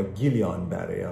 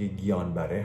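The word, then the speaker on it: Guillain-Barré: سندروم گیان بره یا گیلیان بره البته اون الش اونجوری باید تلفظ بشه که گیان بره هست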